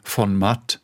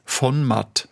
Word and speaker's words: von Matt: Der Name also von Matt, das wird ja im Deutschen wie ein Adelstitel ausgesprochen. Also in Deutschland sagt man von Matt. In der Schweiz spricht man den Namen ganz anders aus. Also in der Schweiz heißt es von Matt, das heißt praktisch von der Wiese.